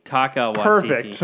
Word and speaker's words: Taika Waititi: Next thing is, God, I'm going to mispronounce this, Taika Waititi? – Taika Waititi.